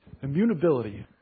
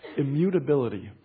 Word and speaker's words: immutability: Immutability. Let me say that again, because I don't think I pronounced it right the first time. Immutability. God's unchangingness.